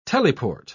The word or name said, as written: teleport